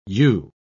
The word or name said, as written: ewe